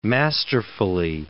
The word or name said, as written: masterfully